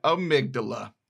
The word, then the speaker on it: amygdala: And then when I was editing, I realized that the fancy new word that I learned, I mispronounced it the entire segment. So when I say amyglia, I mean amygdala.